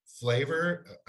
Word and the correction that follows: Flaviar: Flaviar? – Flaviar. Sorry. Thank you. As soon as I was like, dang it, I'm saying that wrong.